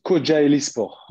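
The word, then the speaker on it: Kocaelispor: Là, actuellement, alors tu me dis si je le prononce bien, mais tu es à Coca Sport. Alors, c'est Koja Elisport.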